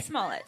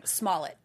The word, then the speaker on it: Smollett: How do you say his last name? – Smollett. – I've been hearing Smollett. – Yeah, like, that's Jussie Smollett.